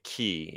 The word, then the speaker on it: Cay: And I always have to force myself to say Key, even though it's spelled K.